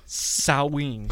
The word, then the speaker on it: Samhain: Okay, you want to know how to pronounce it? – Oh, gosh. No, stop. – It is Samhain. What? S-A-M-H-A-I-N.